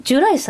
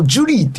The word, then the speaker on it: ジュライ: で、これをジュリーって読む人はその年代の人らしいんですよ。ジュリー。綴りから言うとね。 – ケンジ・サワダですか？ – そうそうそうそう。で、カフェ・ジュライと読むか、カフェ・ジュリーって読むか、まあ、お任せしますやけども、綴りは一緒なんですよ。